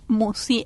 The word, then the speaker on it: Mussi: El canal lleva mi nombre, Marisa Musi. – ¿Cómo se escribe Musi? – Musi, M-U-S-S-I.